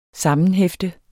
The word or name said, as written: sammenhefte